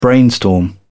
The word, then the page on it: brainstorm: British English